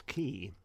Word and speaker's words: Kee: And The other one that's sort of brand new, and I didn't even know it was coming about, is Key. Is that how you pronounce it? K-E-E? – Yeah.